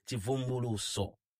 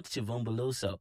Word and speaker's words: Chivumbuluso: I want to pronounce this in my Zambian accent, okay? Chivumbuluso. Not Chivumbuluso. The Chivumbuluso Choir.